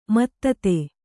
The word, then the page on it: ಮತ್ತತೆ: mattate